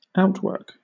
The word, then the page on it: outwork: British English